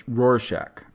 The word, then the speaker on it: Rorschach: How do you say the Rorschach test where she looks at the Rorschach? – Yes, yeah.